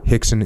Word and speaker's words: Rickson: And by the way, Rickson is spelled with an R, in case you're wondering. That's the Portuguese, the Brazilian Portuguese pronunciation.